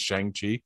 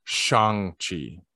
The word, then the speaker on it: Shang-Chi: Yes, and I stumbled across it because that's exactly how I pronounce it too, is Shang-Chi. Apparently, yeah. We've been pronouncing it wrong because we're white. It's Shang-Chi and the Legend of the Ten Rings.